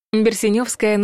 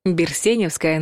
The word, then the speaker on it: Берсеневская: Берсеневская набережная – неправильный вариант. Берсеневская набережная – правильный вариант.